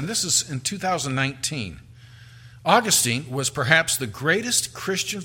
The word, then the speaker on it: Augustine: Um, to understand who Monica is, we have to know who Augustine is, or I think really, uh, uh, probably better pronounce his name Augustine, but um, j- just to give you some appreciation, some comments made about him.